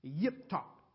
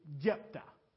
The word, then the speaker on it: Jephthah: That word Jephthah in Hebrew is pronounced Yiptok. But for the sake of English, we'll just say Jephthah.